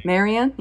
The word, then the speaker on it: Marion: How do you pronounce that beautiful name? – Marion Crane. – Marion. Yeah. Like Marie kind of.